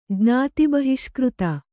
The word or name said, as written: ಜ್ಞಾತಿಬಹಿಷ್ಕøತ